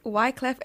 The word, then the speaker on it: Wyclef: Okay, and that was Wyclef and Shakira, Hips Don't Lie.